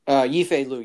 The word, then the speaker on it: Yifei Liu: Yifei Liu, yeah.